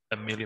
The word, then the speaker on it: Amale: We have Amale versus Amelia McKenzie. Amel. I'm used to French pronunciation. Amel, of course it is.